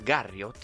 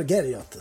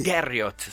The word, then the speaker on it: Garriott: dedicato a Richard Garriott, se non sbaglio. – Richard Garriott, sì. – Garriott, scusa, la prima pronuncia è maccheronica.